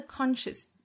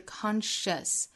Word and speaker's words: conscious: 前面的 conscious 要注意单词内不含有字母 r。不读成 r 的发音，英音和美音都是这样的，不能读成 conscious, 一定是 conscious, conscious, schwa sound, 不要读成 r, conscious entity can ever hope to do.